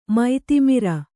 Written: ಮತಿಮರೆ

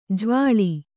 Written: ಜವಳಿ

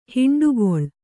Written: ಹುಂಡುಕೋಳಿ

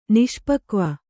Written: ನಿಷ್ಪಕ್ವ

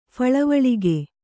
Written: ಫಳವಳಿಗೆ

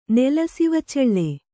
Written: ನೆಲಸಿವಚೆಳ್ಳೆ

